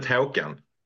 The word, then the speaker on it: Håkan: I pronounce it Håkan.